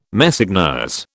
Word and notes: Messeigneurs: British English

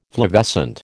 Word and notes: flavescent: American English

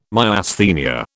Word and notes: Myasthenia: British English

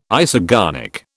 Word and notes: isogonic: British English

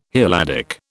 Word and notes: Helladic: British English

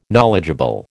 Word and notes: knowledgeable: American English

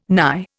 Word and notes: nye: Indian

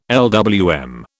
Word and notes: LWM: British English

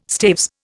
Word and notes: staves: Indian

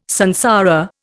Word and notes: samsara: Indian

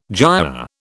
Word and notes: giaour: British English

